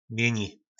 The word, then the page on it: Mjyni: nářečí Po našimu